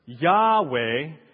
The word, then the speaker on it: Yahweh: which is why today we pronounce this word Yahweh, and this is probably very close to how the ancients pronounced that word as well.